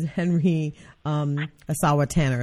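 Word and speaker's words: Henry Ossawa Tanner: which is Henry Asawa Tanner. Is that right? Am I saying it correctly? Yes, I am.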